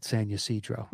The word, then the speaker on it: San Ysidro: Yeah, you know, if you really want to get out of that, I would say it like I say it, San Ysidro.